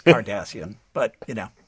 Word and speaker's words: Cardassian: And Patrick, he really wanted to say Kardashians as opposed to Cardassian.